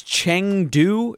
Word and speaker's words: Chengdu: It was, and I looked it up, and it's Chengdu is the name of the city. And it has, which, by the way, that is also the number 5, and it's outstanding. – Chengdu has a population of 16 million.